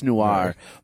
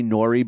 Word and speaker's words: noir: I know it's noir. But just when people who say nori because they just think that's how it's pronounced.